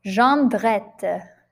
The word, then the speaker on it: Jondrette: Number two, Jondrette.